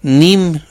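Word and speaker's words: neem: Es la siguiente, es malabar, salguero, palo Brasil, nim, se escribe con doble E, nem, ¿sí?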